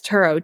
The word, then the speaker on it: Turo: Yes, Turo, T-U-R-O.